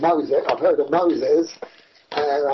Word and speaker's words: Mosec: And I opened my eyes after it started writing again, and a word came up on the page of M-O-S-E-C, Mosec, M-O-S-E-C, I pronounce it, Mosec.